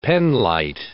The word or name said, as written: penlight